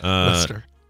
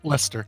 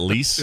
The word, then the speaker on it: Leicester: Leicester. – Leicester. L-E-I-C-E-S-T-E-R. Uh-huh. Okay. – Because that first part is pronounced less, and the second pronounced is stir. – Really? L-E-I-C-E yes. is less? Yep. It's not lease?